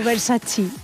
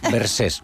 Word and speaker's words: Versace: El Versace, ¿no? Y el Versace. – Eso es, me dicen Versace. – O Versace. Versace, no Versace.